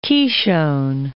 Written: Qishon

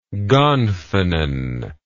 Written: gonfanon

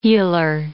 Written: heeler

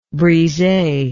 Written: brise

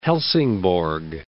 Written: Halsingborg

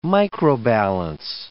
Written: microbalance

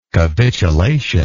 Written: capitulatory

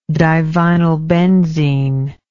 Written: divinylbenzene

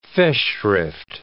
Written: Festschrift

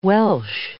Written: Welsh

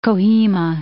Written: Kohima

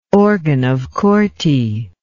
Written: organ of Corti

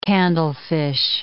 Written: candlefish

